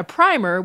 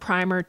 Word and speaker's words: primer: In the US, these two words are often pronounced differently. You get primer for the book and primer for the paint and other things. In British English now, both words are typically pronounced primer.